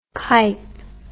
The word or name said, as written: kype